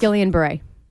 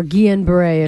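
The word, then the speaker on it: Guillain-Barré: Guillain-Barre. Or Guillain-Barre, if you want to go with the French pronunciation.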